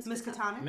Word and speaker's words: Miskatonic: Is that how you say it? – Miskatonic? – Yeah, which I was like, oh, that's kind of fun.